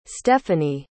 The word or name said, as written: stephanie